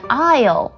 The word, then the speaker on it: aisle: 还有走道啊，就是如果在坐飞机的时候会说你想要靠窗还是靠走道，那个字的拼法是 A-I-S-L-E, 但它的 S 也是不发音的哦，它是念 Aisle, Aisle。